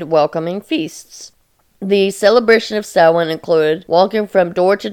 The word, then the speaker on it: Samhain: Its origin comes from an ancient Celtic pagan tradition called Samhain, but it is spelled Samhain, or a lot of people pronounce it Samhain, but it's pronounced Samhain.